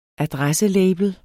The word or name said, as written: adresselabel